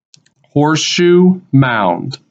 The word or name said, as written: Horseshoe Mound